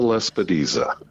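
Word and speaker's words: lespedeza: and the other one I have a hard time pronouncing, Lepisoda? – Yeah, Cerisea lespidiza. Yes.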